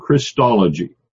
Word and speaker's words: Christology: Yes, it's spelled C-H-R-I-S-T, and we say that Christ, but when you add the ology on it, it becomes Christology, changes the sound of the I from a long I to a short I.